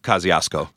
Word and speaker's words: Kosciuszko: And we're saying the right pronunciation is Kosciuszko or something like that. – Oh, God. – But Bed-Stuy, it's Kosciuszko. That's how we pronounce it.